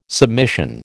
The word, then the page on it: submission: American English